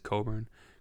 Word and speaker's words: Cockburn: It's spelled Cockburn, but it's Coburn.